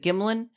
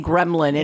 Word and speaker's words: Gimlin: In the Patterson, what is it, Gimlin? I want to keep saying Gremlin, and I know it's not Gremlin.